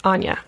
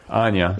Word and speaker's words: Anya: And you like, just for listeners, like, basic storyline, you have Anja. Am I pronouncing that right? – Anya. – Anya, yeah. Sorry. No. I'm thinking it rhymes with ganja.